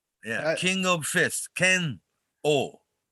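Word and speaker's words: Kenoh: Kenho, people in English speaking world, you know, this hard to pronounce. They always say Ken-O, right? – Mm-hmm. – It's Ken-O.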